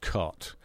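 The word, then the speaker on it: Cait: Her name looks like Kate, but it's actually pronounced Cot.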